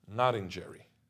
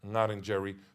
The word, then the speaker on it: Ngarindjeri: and therefore they say Ngarindjeri with a R, R, which is funny because when I say Ngarindjeri, which is the original pronunciation, they would correct me and say, oh, no, it's Ngarindjeri, you know, there is no R, it's R.